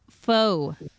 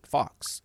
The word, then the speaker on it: faux: You would pronounce it Fox. F A U X. That's how it would sound.